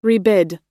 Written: rebid

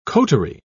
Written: coterie

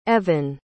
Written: Evon

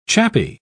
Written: chappie

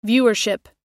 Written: viewership

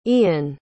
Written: ian

